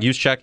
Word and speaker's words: Juszczyk: Cut their fullback, Juszczyk, right? I don't know how to say his last name. – Juszczyk, yeah.